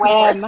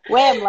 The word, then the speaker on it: WIIM: WIM? Do you say WIM? I always say WIM or WIM. I go back WIM. – And forth. – WIM like women. – WIM like women. Perfect.